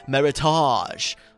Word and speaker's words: Meritage: And you might hear Meritage, which is spelled M-E-R-I-T-A-G-E. And you might have heard some fancy person call it Meritage. But honey, it's Meritage, just like the word heritage.